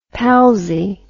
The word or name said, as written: palsie